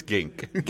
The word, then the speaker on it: Ginck: That is the voice of Nate Gink. It's Gink. – Gink. G-I-N-C-K.